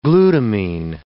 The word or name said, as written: glutamine